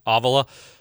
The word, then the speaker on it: Avila: That's how it's spelled and it's pronounced differently. Maybe we can will them in the Sycamores into the NCAA tournament. – Is he leading the Missouri Avila? Valley? – Is that how you said it's pronounced? – Yeah. Mm-hmm. Avila.